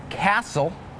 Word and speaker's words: castle: Some examples with a silent T are the words listen, the word castle, and the word Christmas.